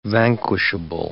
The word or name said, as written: vanquishable